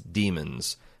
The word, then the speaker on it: daemons: Normally when someone talks about inner demons, which Ghost Dad certainly is, it's spelled D-E-M-O-N-S. Here it's spelled D-A-E-M-O-N-S. Demons.